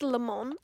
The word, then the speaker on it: Le Mans: I called it Le Mans to my parents because I was like, I know in French they ain't going to be pronouncing the S.